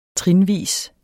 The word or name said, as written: trinvis